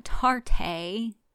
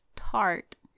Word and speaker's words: Tarte: It's not Tarte, it's Tarte.